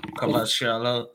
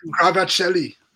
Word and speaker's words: Kvaratskhelia: Kvaratskhelia. – I learned how to say it. – Kvaratskhelia. – Yeah, Kvaratskhelia.